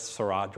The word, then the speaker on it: Seurat: It's spelled like Surratt, but it's Seurat, George Seurat.